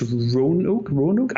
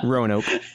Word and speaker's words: Roanoke: but it's Roanoke. I always murder that pronunciation. – Yeah, Roanoke.